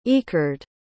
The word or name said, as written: Ekerd